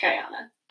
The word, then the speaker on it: Guyana: How do you say it? Guyana? – I think it's Guyana.